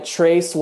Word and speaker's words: Trace: And Trace was a nickname growing up, because Trace is three in Spanish, but it's spelled T-R-A-C-E.